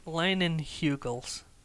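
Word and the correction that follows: Leinenkugel's: It's spelled is that a K? – L-E-I-N-E-N-K-U-G-E-L-S. – So Leinen Kugels. – It's like Kegels.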